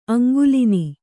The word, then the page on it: ಅಂಗುಲಿನಿ: Kannada